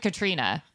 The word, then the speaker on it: Caitríona: I finally learned Katrina, that's how they say it.